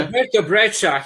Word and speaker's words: Alberto Breccia: So um, I um I was mm, I was closer to a guy called Alberto Breccia. Did I pronounce that right? That's Spanish. See, well, yeah, we're there. Alberto Breccia.